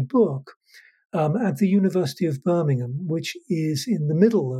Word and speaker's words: Birmingham: And that is Birmingham, not Birmingham, as Americans might. – Right. – And if you want to say it properly, you have to say it like they do in Birmingham, where it's always raining, so everyone always has a cold. So people say Birmingham like that.